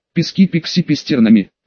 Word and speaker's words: Пески пикси пестернами: Пески пикси пестернами. В обоих словах ударение на вторую часть слова активатора. А теперь все повторяем за мной.